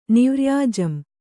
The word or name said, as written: ನಿವ್ರ್ಯಾಜಂ